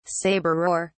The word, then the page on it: sabreur: British English